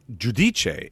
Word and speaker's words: Giudice: I believe it's, if it's Giudice, it would be how it would be pronounced in Italy, right? For the G-I-U is the Jew, and Dice, the D-I-C-E.